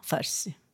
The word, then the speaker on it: Farsi: That I recall from Farsi. Yeah, in English, that's how they say it. – Uh-huh, Farsi.